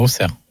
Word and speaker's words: Auxerre: donc les joueurs voudront gagner à Auxerre. – À Auxerre. – À Auxerre, pardon.